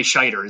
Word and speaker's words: Scheider: Roy Scheider.